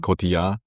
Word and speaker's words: Cotillard: Du bist die deutsche Stimme unter anderem von Jennifer Lopez, Marion Cotillard, wie spricht man es genau aus? – Cotillard.